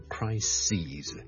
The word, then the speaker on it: crises: 我剛才講了最常見的例子就是 crisis， C-R-I-S-I-S 一個危機， a big crisis， a number of crises， okay，留意個發音，串字當然是不同，但發音是不同， crisis 和 crises。